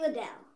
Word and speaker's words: Liddell: Lytle? Sorry. Or Liddell. I don't really know. – I don't know how to pronounce it. It's Liddle. – It's Adele. – Liddell. – Yeah, it's Adele. – EJ Liddell.